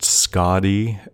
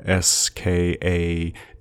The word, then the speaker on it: Skadi: I think it can be pronounced Skadi, Skadi, or Skathe, or Thi, as in Skadi, Skathe, Skadi. And so I'll probably switch interchangeably. I'm not sure there's a single correct pronunciation.